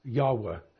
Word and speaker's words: Yahweh: And the right way to pronounce it is Yahweh.